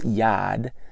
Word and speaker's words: yad: The word power that's used there is this Hebrew word pronounced yad, spelled Y-A-D.